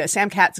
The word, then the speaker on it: Samkhat: And it's spelled S A M K H A T. And that is pronounced Samkhat, correct? – Uh, that's the way I pronounce it.